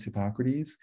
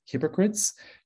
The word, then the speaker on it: Hippocrates: Trouble is, most of the public does not know how to pronounce Hippocrates. And if you were to guess how they mispronounce it, if you think it's hypocrites, you are correct.